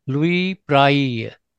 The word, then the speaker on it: Louis Braille: Louis Braille.